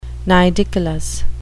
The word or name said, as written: nidicolous